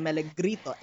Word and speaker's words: Melegrito: Instagram at JM Melegrito. That is JM Melegrito.